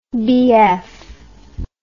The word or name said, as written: BF